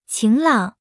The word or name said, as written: 晴朗